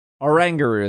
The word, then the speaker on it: Oranguru: Oranguru is how you say it. Orangutan, Oranguru.